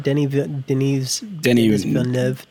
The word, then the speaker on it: Denis Villeneuve: The Denis Villeneuve. Am I saying that right? Denis Villeneuve. Okay. – Correct.